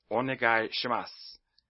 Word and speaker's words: Onegai Shimasu: The next phrase is Onegai Shimasu, and it's okay if you pronounce the U, because beginners will do that generally, though sometimes the U will disappear, especially when you speak faster.